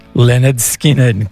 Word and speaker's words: Lynyrd Skynyrd: Gimme Three Steps appears on Lynyrd Skynyrd's debut record, which is called, pronounced, Lynyrd Skynyrd. Go figure.